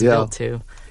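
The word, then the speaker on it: Wild 2: It's spelled Vilt like 2. The word wild, but it's pronounced Vilt 2.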